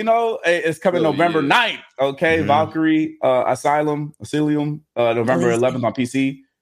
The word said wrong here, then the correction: Elysium: I don't know how to say it. Should we say it again? – Elysium.